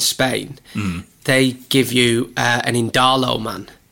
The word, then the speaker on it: Indalo: I just pronounce it as in Darlington, as in Indalo. Oh, Darlo man. Oh, Indalo man.